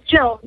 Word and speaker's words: Jill: Jill. It's Jill, like J-I-L-L.